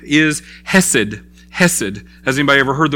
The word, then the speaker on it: Chesed: So Hesed is this, um, and I think to say it properly in Hebrew, it's, it's Chesed. Um, because you see it's spelled with an H, and you also see it spelled with a CH. And so when you see that, you know that in Hebrew it's a ch sound. – So it's a chesed.